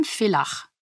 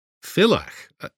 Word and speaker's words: Villach: I'm from Villach, in Austria. – Villach? I don't know it. How do you spell it? – V-I-L-L-A-C-H.